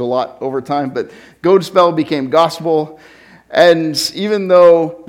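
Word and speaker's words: godspel: in Old English got translated as God spell, G-O-D, long O, spell, S-P-E-L. So God spell was the word, and God spell simply meant good news or good story.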